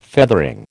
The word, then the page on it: feathering: American English